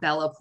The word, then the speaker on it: Bella: I'm on Twitter and Instagram at Bella Pori, which Bella spelled like Twilight, Pori, P-O-R-I.